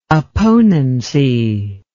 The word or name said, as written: opponency